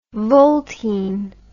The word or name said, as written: voltine